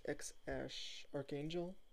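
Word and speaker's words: X Æ A-12: The name is, it's spelled X space AE space A12, and it's pronounced X Ash Archangel,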